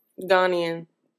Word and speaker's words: Ghanaian: You said Guyana. I can't say it. – Ghanaian. – That's how you say it? – I believe so.